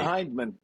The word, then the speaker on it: Hyndman: So let me see, in pronouncing your last name, is it Hyndman? How do I say it? – Hyndman. – Like I said, Hyndman? – It's the Scottish version.